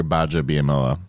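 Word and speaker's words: Gbadja-Biamila: The G is silent. – Is it? – It's just Kabir Gbadja Biamila.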